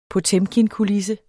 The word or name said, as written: POTEMKINKULISSE